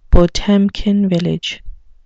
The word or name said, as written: potemkin village